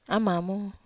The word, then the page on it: amamo: Wamesa